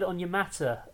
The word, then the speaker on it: Onyemata: Next up, David Onyemata, the Falcons, three-year, $35 million deal.